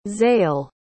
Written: xael

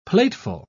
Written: plateful

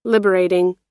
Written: liberating

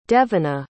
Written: devona

